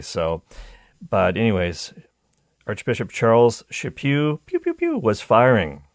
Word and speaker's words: Chaput: Not Chaput, as some people mispronounced his name. No, no, no. It's pronounced Chaput. He's one of the leading churchmen in the United States. Like, pew, pew, pew. That's how you pronounce it.